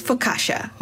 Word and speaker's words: focaccia: Before we get started, it's focaccia. – Focaccia. – Focaccia. – Yeah, but it's okay. We all understand.